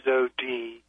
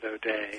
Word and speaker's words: O'Dea: James O.D., that's spelled O-D-E-A. Even though in Washington State they pronounce it O'Day.